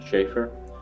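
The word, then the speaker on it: Schaeffer: Jonathan Schaefer, J-O-N-A-T-H-A-N-S-C-H-A-E-F-F-E-R.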